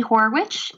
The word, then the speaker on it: Horwitch: You can find me on Instagram at Katie Horwitch. It's my entire name. It's WITCH. Some people leave out the T. It's spelled like witch.